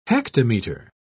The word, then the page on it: hectometer: British English